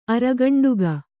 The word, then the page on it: ಅರಗಂಡುಗ: aragaṇḍuga